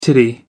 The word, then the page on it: titty: American English